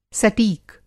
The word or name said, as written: सटीक